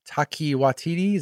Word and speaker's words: Taika Waititi: Kathleen Kennedy was asked about Taika Waititi. Is that how you say his name? – Yeah, I think you nailed it.